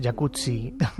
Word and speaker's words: Jacuzzi: Jacuzzi.